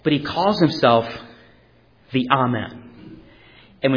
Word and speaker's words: Amen: It is pronounced Amen in Greek, and in Hebrew it's about the same thing, Amen.